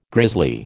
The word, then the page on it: grizzly: American English